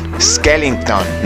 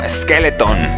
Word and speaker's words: Skellington: Pero bueno, en esta película, Jack Skellington, Skellington, no Skeleton, para que vean cómo se dice de verdad.